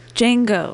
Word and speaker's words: Django: I think the D is silent, right? – Django.